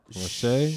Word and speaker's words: Raiche: That was Rach Reich. I don't want to. I know I'm butchering her name. R A I C H E. – Okay. – Raiche. Raiche.